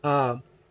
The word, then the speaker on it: Moshe: And just so you all know, Moshe is how you pronounce Moses' name in Hebrew.